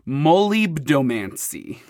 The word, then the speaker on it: molybdomancy: molybdomancy.